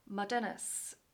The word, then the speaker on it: Modernus: Veronica is the CEO of Modernus.